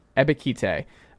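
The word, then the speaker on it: Ebiketie: Do you know how do you say it? – Ebikite. – Ebikite.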